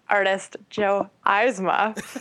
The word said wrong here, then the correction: Eisma: Eyes, it's pronounced Eisma. Right.